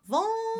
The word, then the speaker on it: vulnerable: Vulnerable. Vulnerable. – There we go. All syllables. Vulnerable.